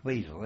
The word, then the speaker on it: Wesel: V-E double S E L, Wesel. W E double S E L. But it's pronounced Wesel, isn't it?